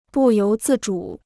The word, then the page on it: 不由自主: bù yóu zì zhǔ